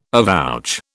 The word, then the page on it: avouch: British English